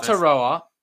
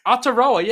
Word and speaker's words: Aotearoa: that Aotearoa Mary. – No, that's not how you say it. Aotearoa, yeah, that's how you say it.